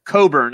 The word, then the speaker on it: Cockburn: Yeah, Coburn is his name, but it's it's spelled Cockburn.